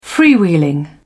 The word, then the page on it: freewheeling: British English